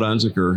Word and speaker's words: Unzicker: And I have the distinct pleasure to hang out with my friend Todd Unzicker, who is the executive director of the North Carolina Baptist Convention.